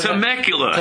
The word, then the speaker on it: Temecula: Temecula. – Temecula, California.